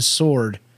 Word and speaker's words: sword: It's not the first time that's happened where somebody pronounces the W in sword.